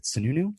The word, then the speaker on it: Sununu: And then the governor, Chris, what is it, Sununu? – Yep. – Is that how you pronounce it? – Yeah.